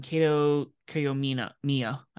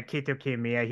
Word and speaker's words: Kiyomiya: Hikaleo, Ren Narita, Shota Umino, Yota Tsuji, Gabe Kidd, and Kaito Kiyomiya. I totally butchered that. – He's from Noah. Yeah, Kaito Kiyomiya. He's from Pro Wrestling Noah.